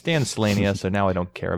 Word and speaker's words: Slania: I'm going to confirm that it's pronounced the way that I think it is. It's not Slania. Oh, it is Slania.